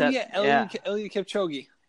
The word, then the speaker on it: Eliud Kipchoge: Kipoge, I think, is that how you say it? – Who? – Ulid, who's just Eliud Kipchoge.